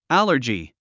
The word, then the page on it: allergy: ǽlə r dʒi; アラジィ